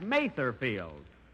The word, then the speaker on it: Mather Field: Here it is, Mother's Day, and we're at Mother Field. – That's Mather Field. And although I pronounced it wrong last week, I mean, and speaking of Mother's Day, speaking of Mother's Day, Phil, did you remember your mother? – Yeah, she's a little white-haired lady, about 5'2".